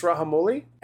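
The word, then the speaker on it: Rajamouli: I think it's pronounced Rahamouli,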